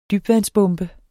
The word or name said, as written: dybvandsbombe